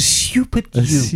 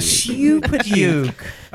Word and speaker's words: Super Duke: You said it wrong. It's a Super Duke. A Super Duke.